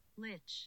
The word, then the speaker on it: Lich: Pronounce Lich. I feel like I've heard it so many times. – Never heard it. Hold on. – Lich. Okay, cool. Confirmation from Google that I'm correct.